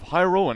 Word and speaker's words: Hyrule: is like Hyrule, that's how I've always said it, but is that way off? – Yeah, Hyrule.